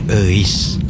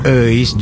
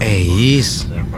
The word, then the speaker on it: Euis: Itu tuh nama Sunda, Bro, dibacanya Euis. – Oh. – Selamat siang, Pak. Maaf, sabuk pengamannya mohon dipasang ya, Pak. – Iya, Mbak. Euis. – Euis, bukan Euis.